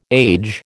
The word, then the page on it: age: American English